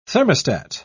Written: thermostat

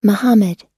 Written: muhammad

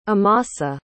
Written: amasa

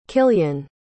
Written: killian

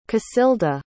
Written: casilda

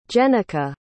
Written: jennika

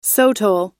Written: sotol

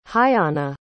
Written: Huyana

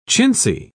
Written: chintzy

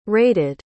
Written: raided

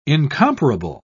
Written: incomparable

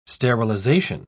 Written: sterilization

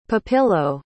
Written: pepillo

